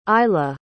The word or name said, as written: ilah